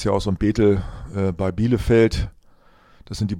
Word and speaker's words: Bethel: Und ja, Bethel Church in Bethel, dann Englisch ausgesprochen, in Redding, in Kalifornien, USA.